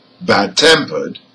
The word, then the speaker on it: bad-tempered: Now, however, for compound nouns with adjectives, let's listen to an example, bad-tempered, the stress is on the second part.